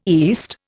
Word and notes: east: Indian

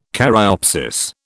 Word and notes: caryopsis: British English

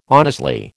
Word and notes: honestly: American English

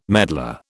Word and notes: medlar: British English

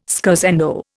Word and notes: scherzando: Indian

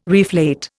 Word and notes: reflate: Indian

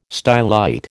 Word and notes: stylite: American English

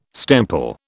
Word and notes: stemple: British English

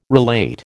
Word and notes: relate: American English

